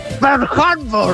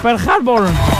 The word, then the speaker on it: Pearl Harbor: Se pronuncia así. – Pearl Harbor. – Eso. Pearl Harbor.